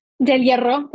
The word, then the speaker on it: Del Hierro: Did I say your last name correctly? – Del Hierro, but that's okay. – Oh, it's because I can't roll my R's.